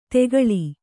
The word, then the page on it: ತೆಗಳಿ: tegaḷi; kannada